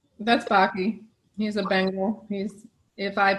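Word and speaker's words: Baci: So in Italian, I was told that kisses is Baki in Italian.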